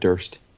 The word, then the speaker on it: Durst: Fred Durst. – Nah. Fred Durst is the worst. – Chocolate starfish. – It's so fitting that his name rhymes with worst.